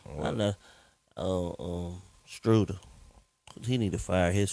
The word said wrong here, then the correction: Schroeder: He don't never say his name right. – This is how he said it. – Schroeder. – Schroeder.